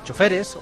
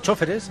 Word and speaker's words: choferes: Por cierto, es además, cosa que yo no sabía, patrona de campesinos, electricistas, choferes, o choferes, admite las dos pronunciaciones, afiladores, cortadores y escritores.